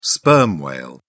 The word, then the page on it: sperm whale: British English